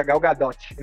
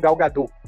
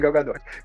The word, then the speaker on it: Gadot: É, a nível de conhecimento, gente, ela mesma já deu entrevista falando que a, a, o nome dela se pronuncia Gal Gadot, não Gal Gadot, e sim Gal Gadot.